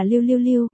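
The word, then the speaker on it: lưu lưu lưu (六六六): Bởi vì đặc biệt lục lục lục, bản gốc in way te bi lưu lưu lưu, lục lục lục phát âm là lưu lưu lưu, gần giống niu niu niu.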